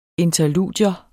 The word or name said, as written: interludium